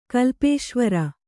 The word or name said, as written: ಕಲ್ಪೇಶ್ವರ